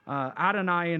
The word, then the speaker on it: Adonai: It is Yah, or Yod, He, Vav, He, but it's pronounced Lord. It's pronounced Adonai in Hebrew, Kurios in Greek.